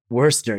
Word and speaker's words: Worcester: Worcester, yes. And it's spelled weirdly. Like the Worcester sauce, I guess.